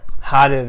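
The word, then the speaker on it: Haru (春): Isn't it Haru. – Haru, very good.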